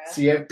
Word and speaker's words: Ciara: Ciara. – Damn, man. How do I botch a name right away? – Like the singer.